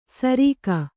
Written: ಸರಿಗ

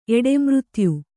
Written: ಎಡೆಮೃತ್ಯು

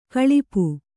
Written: ಗಳಪು

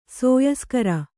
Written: ಸೋಯಿಸ್ಕರ

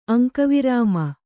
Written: ಅಂಕವಿರಾಮ

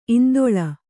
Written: ಇಂದೋಳ

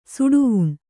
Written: ಸುಡುವುಣ್

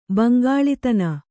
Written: ಬಂಗಾಲಿತನ

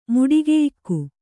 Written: ಮುಡಿಗೆಯಿಕ್ಕು

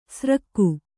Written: ಸ್ರಕ್ಕು

